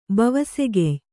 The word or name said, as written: ಬವಸೆಗೆಯ್